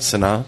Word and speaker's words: Sanaa: Am I saying that right, Sanaa? Mm-hmm.